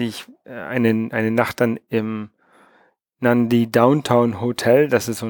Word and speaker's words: Nadi: Das wird Nadi geschrieben, aber Nandi ausgesprochen.